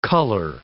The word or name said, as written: colour